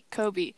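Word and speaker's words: Coby: Kobe.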